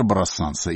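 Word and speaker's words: Brassens: потому что по-французски он произносится «Брассенс», несмотря на сочетание «ЕН», которое вроде бы должно читаться как «Ан», тем не менее «Брассенс».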